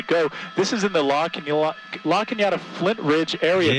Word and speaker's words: La Cañada: And La Cañada Flint Ridge, you've got to really, you know, it's spelled La Canada, C-A-N-A-A-D-A. And unless you're born and raised here, you don't know how they pronounce La Cañada.